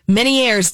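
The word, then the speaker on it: Ménière's: I think it's Meniere's disease. – You are correct.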